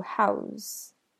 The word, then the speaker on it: how's: That can be shortened into how's, how's, how's. The S is pronounced as a Z sound, just like in he's and she's.